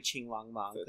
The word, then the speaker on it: Qinglongmon: Yeah, so it's probably Qinglongmon. Because the Q in Mandarin is pronounced like a CH, kind of.